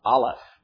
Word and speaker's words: Aleph: The first thing that you might see is a word that's pronounced Aleph. A-L-E-P-H.